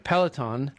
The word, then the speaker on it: Peloton: Peloton.